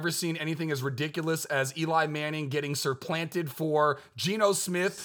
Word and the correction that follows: supplanted: Did I say it wrong? – Yes. – Supplanted? – There you go.